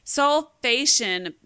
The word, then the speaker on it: sulfation: We're not going to deep dive into it, but you talked a little bit about methylation, and is it sulfatation? Am I saying that right? – Sulfation.